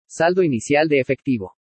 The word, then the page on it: SALDO INICIAL DE EFECTIVO: spanish